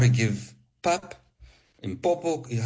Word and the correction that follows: Mpoko: how do you say it? – Mpoko. – You know, that pap with milk. – Yep. Mpoko. Yep.